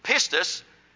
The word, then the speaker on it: pistis: If you look up faith in the Greek language, the word faith is spelled like this. P-I-S-T-I-S. P-I-S-T-I-S, which is pistis.